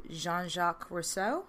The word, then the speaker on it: Jean-Jacques Rousseau: Around this time, this French philosopher and writer named Jean-Jacques Rousseau.